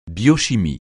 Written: biochimie

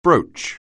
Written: broach

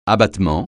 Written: abattement

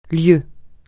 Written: lieue

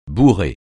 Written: bourrer

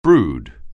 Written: brood

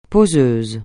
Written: poseuse

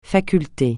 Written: faculté

